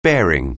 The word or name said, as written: bearing